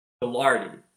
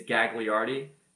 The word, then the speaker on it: Gagliardi: And I also want to emphasize there is a second G in Gilardi. It's, if you were to read it phonetically, it's Gagliardi. But just like Lil Wayne, real G's move in silence, as does John Gagliardi.